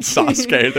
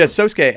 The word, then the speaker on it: Sosuke: It's almost like there's such a lens of Sosuke. – I was gonna say his name's not Sasuke. There's no way. But yeah, Sosuke.